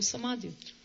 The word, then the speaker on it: samadhi: ovde je osoba koja je u samadhiju. – But Krishna says something very interesting about samadhi in the Bhagavad Gita. Samadhi, actually, is the right pronunciation.